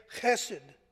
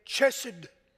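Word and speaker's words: chesed (חסד): Or as my brothers in Lee County, Virginia, the good old rednecks from Lee County would say, chesed. But actually it's pronounced, the Hebrew word is pronounced as an H. The CH is a H sound, chesed.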